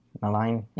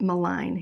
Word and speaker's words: Milne: Milne, I believe Milne. – I always pronounced it Malign.